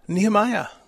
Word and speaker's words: Nehemiah: In Hebrew. No, it is the same. It means the Lord consoles, Nehemiah in Hebrew. But in English, it's Nehemiah. That's, as far as I know, the correct pronunciation.